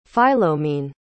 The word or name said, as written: Philomene